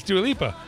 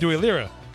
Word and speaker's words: Dua Lipa: It's Dua Lipa, but not Dua Lira, which is different.